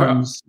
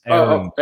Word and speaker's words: Ahrens: By the way, it's Ahrens, Drew. You got to practice to pronounce his name correctly. It's not Ahrens, it's Ahrens.